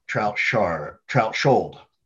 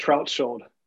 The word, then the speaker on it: Trautschold: we're joined here, and I'm going to butcher it even though he just told me, Brian Trautschold, right? Brian? – Yeah. – Right. – That's right. – Okay. Trautschold. Yep. Yeah. Trautschold,